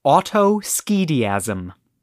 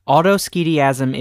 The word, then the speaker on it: autoschediasm: And that word is autoschediasm. – Oh, no. Autoschediasm. – Yeah, I'll spell it for you. – Boy, you're not pulling any punches. – I'm not pulling any punches. Autoschediasm is spelled A-U-T-O-S-C-H-E-D-I-A-S-M.